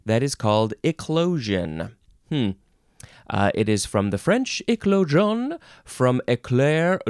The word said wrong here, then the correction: éclore: no, how do you say that? Éclore, which means to hatch.